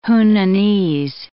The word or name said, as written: Hunanese